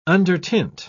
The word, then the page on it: undertint: British English